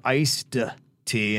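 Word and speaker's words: Ice-T: Was it iced tea or iced tea? And um, Ashley said, Well, how does the rapper, actor, pronounce his name? And it, he's iced tea.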